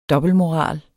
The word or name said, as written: dobbeltmoral